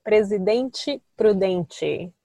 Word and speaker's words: Presidente Prudente: It's called Presidente Prudente.